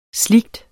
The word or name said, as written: slig